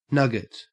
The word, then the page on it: nugget: Australian English